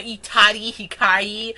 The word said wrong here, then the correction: Utada Hikaru: I said Utari Hikaru. You weirdo. – It's Utada Hikaru. You stupids.